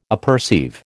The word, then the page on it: apperceive: American English